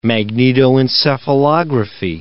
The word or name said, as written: magnetoencephalography